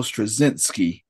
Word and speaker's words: Straczynski: Yeah, as one of the names that popped up when I was researching this show, and I always mispronounce his last name, but one of the head writers was J. Michael Straczynski. – You did it.